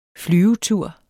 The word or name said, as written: flyvetur